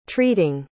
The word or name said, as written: treating